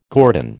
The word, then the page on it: guerdon: American English